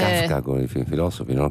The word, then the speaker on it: Kafka: Poi eh, un barista che era un po' filosofo lo, chiamavano, eh, lo chiamavamo Kafka con l'accento sulla A.